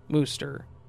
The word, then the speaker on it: Worcester: Although even with the CH spelling in my old reality, it was still pronounced Wooster.